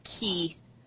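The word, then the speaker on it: Chi: Oh, that's, yeah, that's pronounced key. That's the Greek symbol key, which is the most confusing, horrible.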